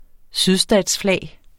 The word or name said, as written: sydstatsflag